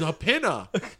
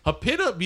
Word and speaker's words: Jupiña: How do you pronounce when it's got the little thing over the N? – It's Jupiña. Jupiña. You know, they can't. Los americanos no pueden decir Ñ, so forget it.